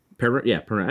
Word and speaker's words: Perron: Perron, I said it right.